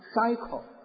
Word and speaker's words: psycho: 英文没有语法，很简单，他看法跟我一样所以我们看到呢这个 PCK 我们看到如果翻成英文就叫 cycle，他 P 不发音 cycle，对不对？